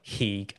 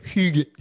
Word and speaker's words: hygge: It looks like Heeg, H-Y-G-G-E. I think it's pronounced like Hygge.